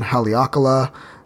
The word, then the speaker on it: Haleakala: It was up on Haleakala,